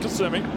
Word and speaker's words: Kissimmee: Kissimmee. – Florida? – Kissimmee? – It said Kissimmee, right? – Yeah, they pronounce it Kissimmee.